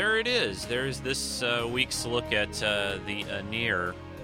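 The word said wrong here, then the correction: Aenar: This episode, did I say that wrong again? Enar. Gosh, I keep doing that. Excuse me. Sorry about that. Enar. The Enar.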